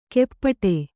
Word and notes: ಕೆಪ್ಪಟೆ: keppaṭe; dial.

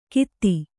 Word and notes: ಗೀತಿ: gīti

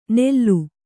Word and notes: ನೆಲ್ಲು: Kannada

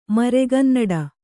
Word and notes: ಮರೆಗನ್ನಡ: maregannaḍa

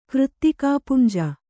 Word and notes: ಕೃತ್ತಿಕಾಪುಂಜ: křttikāpunja

